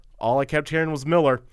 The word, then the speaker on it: Millar: because all the videos I found were calling him Millar. So I'm going with Millar as the pronunciation.